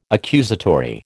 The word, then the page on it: accusatory: American English